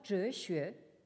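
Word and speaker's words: zheshue (哲学): Alors, donc, vous avez ce composé qui se prononce en japonais tetsugaku et en chinois zheshue, et qui signifie littéralement donc xue, l'étude de zhe, c'est-à-dire donc de la sagesse.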